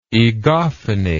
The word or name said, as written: egophony